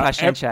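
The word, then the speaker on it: Paciência: Gonçalo Paciencia, I think is how you say it.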